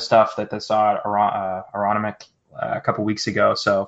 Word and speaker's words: Aronimink: Well, apparently that's how we pronounce that golf course that we couldn't figure out 2 weeks ago. Aronimink. – Aronimink.